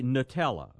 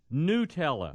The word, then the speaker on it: Nutella: There are people who say Nutella, okay? – Right. – So the beginning sounds like, you know, nut. But the right way to say it is Nutella.